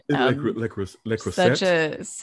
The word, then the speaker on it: Le Creuset: Oh, Le Creuset. I don't even know how to say it. – Le Creuset.